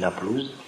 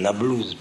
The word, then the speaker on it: Naplouse: Shrem, c'est Naplouse des Arabes, parce que c'est les Romains qui ont appelé Shrem Naplouse. Les Arabes l'appellent Nablouse parce qu'ils ne savent pas prononcer le P. Vous savez ça. Les Arabes ne prononcent pas le P, alors ils disent Nablouse.